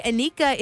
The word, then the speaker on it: Anika: R-E-L-I-Q-A is how you spell it. But hey, Anika is my name. A-N-I-K-A is how you spell that.